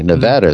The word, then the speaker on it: Nevada: That's wrong, apparently. Nevada is what you're supposed to say.